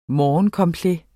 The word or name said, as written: morgencomplet